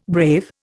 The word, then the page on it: brave: Indian